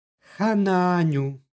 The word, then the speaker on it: Hananju: stihu izgovorili smo Hananju. I ovdje imamo, iako pišu dva a, jedno, u izgovoru jedno duže a.